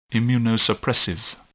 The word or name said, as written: immunosuppressive